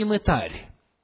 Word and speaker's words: мытарь: Если же не послушает их, скажи церкви, а если и церкви не послушает, то да будет он тебе, как язычник и мытарь. Видите, ударение поставлено, чтобы мы правильно говорили. Мытарь.